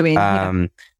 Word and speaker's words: benzoin: I never know how to say this. Benzoin? – Yeah. Benzoin. – Benzoin. Thank you.